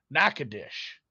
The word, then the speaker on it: Nacogdoches: And then real quick, from the editorial department in the chat, someone who's probably from the area says it's pronounced Nacogdoches. I tried to give it the Nacogdoches treatment, which is a city in Texas, the home of Stephen F.